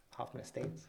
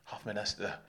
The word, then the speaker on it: Hoffman Estates: Hoffman Estates. – Hoffman Estes. I used to work with a guy. He would always call it Hoffman Estes. I'm like, that is not how you say it.